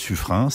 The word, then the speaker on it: Suffren: On prononce en fait Suffren.